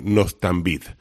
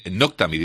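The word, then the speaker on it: Noctamid: Bueno, y al final acaban dándole Noctambid. Esta sí es un medicamento que yo no sé lo que es exactamente. – Noctambid, imagino, ¿no? – Sí, perdón, lo he pronunciado mal.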